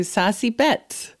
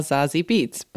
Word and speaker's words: Zazie Beetz: Yes, today we're talking to Sassy Betts, who some people call Zazie Beetz. But I went down the rabbit hole and she says her name in the interview. And she's half German. So it's a German name and that's how you pronounce it.